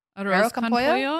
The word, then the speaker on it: arroz con pollo: arroz con pollo? – Arroz con pollo, I think. – In Spanish, do you pronounce the Z? – Yeah, you pronounce arroz almost every.